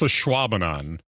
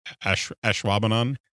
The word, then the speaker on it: Ashwaubenon: It's Ashwaubenon. But okay. If you look at the pronunciation of it, they make it look like ash. We say the A a little bit shorter as Ashwaubenon.